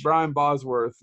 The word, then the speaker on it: Brian Bosworth: But I do not like Brian Boswick. I can tell you that much. – Brian Bosworth. You Whatever. – Don't even know he said his name right.